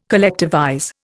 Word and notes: collectivize: Indian

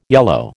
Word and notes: yellow: American English

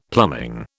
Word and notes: plumbing: British English

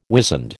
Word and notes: wizened: American English

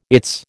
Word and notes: its: American English

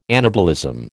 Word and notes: anabolism: American English

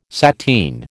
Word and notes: sateen: American English

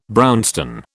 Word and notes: brownstone: British English

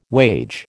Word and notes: wage: American English

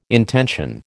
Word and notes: intension: American English

